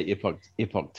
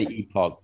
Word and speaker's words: Epoch: I just say Epoch Times, but some people say Epoch, and to say Epoch because that's how New Zealanders pronounce things. Epoch is how most of the people who work there say it. They've got people from all over the world working there, so they say it different ways too.